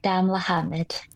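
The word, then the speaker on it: Damelahamid: The festival director, but also the executive and artistic director of Dancers of Damala. Wait, now I mispronounced that. Could you pronounce it for me properly? – Yes, it's the Dancers of Damla Hamid.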